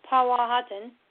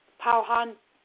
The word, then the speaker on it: Powhatan: Now, Pocahontas was the favorite daughter of Powhatan. I'm sorry, Powhatan. Let's make sure we say that correctly and give him the respect that he has earned.